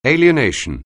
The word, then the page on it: alienation: English